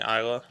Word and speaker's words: Islay: That was from the Islay? – Yeah, it's an Islay, which is spelled Islay. I said Islay for the first, long time, but it is pronounced Isla for and our listeners.